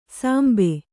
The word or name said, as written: ಸಂಬೆ